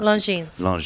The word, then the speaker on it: Longines: Yeah, how do you say that? – Longines. – It's French.